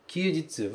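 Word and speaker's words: kyujitsu (休日): Se pronuncia kyujitsu. Kyujitsu, ok? Esse kanji de dia que vem no final, que significa sol também, aqui ele é lido jitsu.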